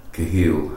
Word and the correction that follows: Khalil: Khalil Gibran.